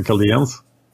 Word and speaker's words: Chaldeans: The Chaldeans that I pronounced differently every time, but I think the correct English pronunciation is the Chaldeans, right? – Yeah, that's what I'm going with.